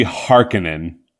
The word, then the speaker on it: Harkonnen: It's said in the movie Harkonnen.